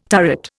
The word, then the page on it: turret: Indian